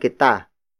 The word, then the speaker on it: kita: For example, magkaibigan kita.